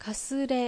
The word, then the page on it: Kasure: Japanese